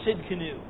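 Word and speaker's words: Tsidkenu: That's a hard one to say. Sounds like sitting in a canoe. That's how I remember how to pronounce it. Jehovah Sidkenu, which means Jehovah is our righteousness.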